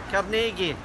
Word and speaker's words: Carnegie: As for the differing pronunciations of his last name, well, we'll let the people of his hometown in Scotland have the final word. – Carnegie.